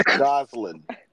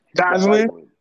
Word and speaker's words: Gosling: How you say it? – Gosling. – Gosling.